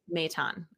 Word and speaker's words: Maton: Is that how you say it? Maton? – Maton.